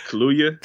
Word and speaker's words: Kaluuya: I always want to say Daniel Kaluuya, like the fucking drink. how do you say his last name? – Kaluuya. – Kaluuya. – Kaluuya. – Yeah. – That's what I've been saying.